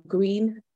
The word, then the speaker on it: Greene: but my name is spelt L-U-C-I-E and surname Green, G-R-E-E-N-E, crucially with the E.